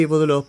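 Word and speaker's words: Boudleaux: Nacía en el 25, murió en el 2003 y él, Diodorius Bodlo Bryant, acá tengo la pronunciación, sí, Bodlo parece que es, nació en el 20 y murió en el 87.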